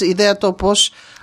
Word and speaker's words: Ideatopos: It's Ideatopos idea. I'll try to say it a little bit with a Greek English pronunciation. It's idea. Topos, which means place in Greek, .org.